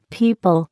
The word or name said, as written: people